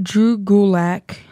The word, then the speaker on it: Drew Gulak: I got Drew Gulak. I probably butchered that. Who cares? No, that sounds pretty good to me.